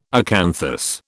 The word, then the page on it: acanthus: British English